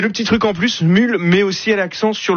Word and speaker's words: Smule: Ça s'appelle Smule. Hein, Smule, ça s'écrit S-M-U-L-E, avec plusieurs centaines de millions de téléchargements.